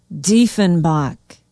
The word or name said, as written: diefenbach